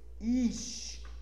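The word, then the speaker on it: ish (אִישׁ): Or I is in English, but in Hebrew it's ish. E-E-S-H is the pronunciation.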